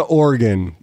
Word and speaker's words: Oregon: And Oregon's like, nobody cares. You didn't even say no one cares. – It's pronounced Oregon. So Oregon. That's shame on you.